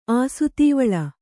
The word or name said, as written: ಆಸುತೀವಳ